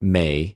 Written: μη

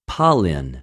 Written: παλιν

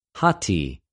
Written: ὁτι

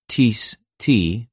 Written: τίς

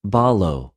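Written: βαλλω

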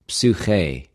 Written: ψυχη

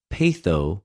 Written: πειθω

